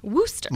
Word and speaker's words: Worcester: Worcester? – Worcester. – Like Woo Sox.